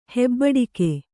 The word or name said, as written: ಹೆಬ್ಬಡಿಕೆ